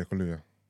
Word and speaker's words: Kaluuya: Kaluuya? – Yeah, Kaluuya.